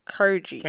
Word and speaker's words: Kurgi: Krugy. – Huh? – Krugy. Kurgy. I mean, Kurgy. – Can you spell it? – K-U-R-G-I. – K-U-R-G-I.